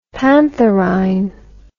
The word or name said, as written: pantherine